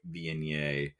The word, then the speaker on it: Viognier: I have a 425 Cellars Picnic Point Viognier. Not too bad. And yes, I nailed the French pronunciation, so I got that going for me, which is nice.